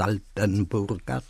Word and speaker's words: Altenburg: Ale główne prace budowlane rozpoczęto za czasów wielkiego mistrza Dytryka z Altenburga, tak to czytam, bo nasi historycy wolą brzmienia oryginalne, więc Dietrich von Altenburg, można też tak powiedzieć, jeszcze w wymowie jakiejś, na przykład saskiej, Altenburg.